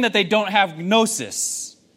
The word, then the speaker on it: gnosis: Normally, in Greek, when you were to say knowledge, you would use the word gnosis with the silent G.